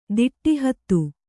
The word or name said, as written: ದಿಟ್ಟಿಹತ್ತು